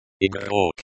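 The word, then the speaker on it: igrok (игрок): The word igrok. Igrok. Is pronounced igrok. The stress is on the second syllable.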